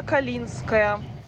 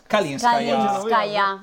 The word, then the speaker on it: Kalinskaya: Anna Nikolaevna Kalinskaya. – Kalinskaya. – Anna Nikolaevna Kalinskaya.